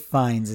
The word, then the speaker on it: Fiennes: I would personally like to recommend the film The Menu, which stars Anya Taylor-Joy and Ralph Fiennes. Is that how you pronounce his last name? – Ralph Fiennes is how you pronounce his name. Ralph Fiennes.